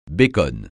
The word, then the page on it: bacon: francais